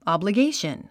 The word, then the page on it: obligation: ὰbləɡéiʃən; アブリゲイション